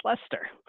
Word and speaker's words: Leicester: Since I've now learned to pronounce them, I'm going to choose Lester. Congrats on the pronunciation.